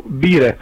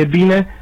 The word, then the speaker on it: bine: Istro-românii pronunță, spre exemplu, bire în loc de bine, spure în loc de spune.